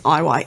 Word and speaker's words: Iyer: I quite like in the post, so my last name is Iyer. – Yeah. – I'll often get Lyer.